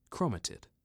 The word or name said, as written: Chromatid